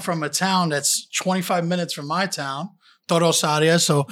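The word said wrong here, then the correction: Torre Orsaia: Okay, I'll say it. Listen, watch my lips. Torre Orsaya.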